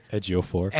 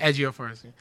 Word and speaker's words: Ejiofor: No, but like, Be Prepared was more like a spoken word because Chiwetel Ejiofor, did I say it right? – Ejiofor. – Ejiofor. – Yeah.